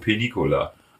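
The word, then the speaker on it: Peñíscola: Nee, der wird ausgesprochen Penicola. – Ah. – Aber er wird geschrieben. Penis Cola.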